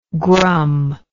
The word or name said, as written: grum